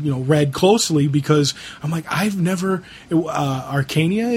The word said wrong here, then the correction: Archaea: is it? – It's Archaea.